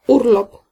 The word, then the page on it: loisirs: France; /lwa.ziʁ/